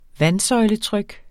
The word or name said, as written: vandsøjletryk